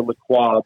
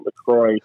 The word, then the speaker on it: LaCroix: I always pronounce it LaCroix, but they've Americanized it and they pronounce it LaCroix.